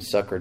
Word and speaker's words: succor: I like to say succor. I don't know. Pronunciation sucker just doesn't have enough reverence to it, but that's how it's pronounced actually.